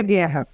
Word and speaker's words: Guerra: Sé que en portugués se pronunciará diferente tu apellido, pero no me voy a animar a intentar. – Julia Guerra.